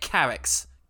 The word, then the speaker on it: Carax: Oh, is it Carax? Carre sounded more French. – Surprising man. It did, yeah. But I think you pronounce the X and the T's you don't pronounce.